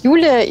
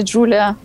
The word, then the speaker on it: Julia: Да, у нас выпуск про язык Юлия, и главный вопрос, как его правильно произносить, Юлия или Джулия? – Я думаю, что нету какого-то такого благословленного официального произношения. Мне кажется, разные пользователи языка произносят по-разному. Я лично чаще говорю Джулия, но это просто дело привычки, ну и окружение мое, естественно, которое англоязычное, но э, чаще тоже использует Джулия.